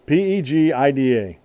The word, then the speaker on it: Pegida: PEGIDA. PEGIDA. Pegida. In my how to pronounce it, it's pronounced Pegida.